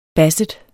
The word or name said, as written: basset